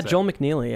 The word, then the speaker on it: McNeely: Am I saying it right, Joel McNeely? – Yeah, Joel McNeely. Yeah, that sounds right to me.